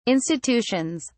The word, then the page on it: institutions: British English